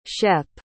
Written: shep

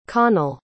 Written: connell

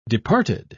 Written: departed

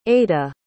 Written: ayda

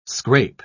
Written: scrape

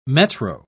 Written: metro

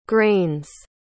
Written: grains

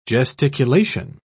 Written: gesticulation

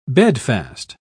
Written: bedfast

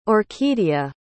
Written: orquidea

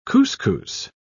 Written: couscous

